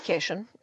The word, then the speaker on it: Kashian: Well, my name is Jackie Cation, and Cation is spelled Kashian.